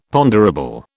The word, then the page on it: ponderable: British English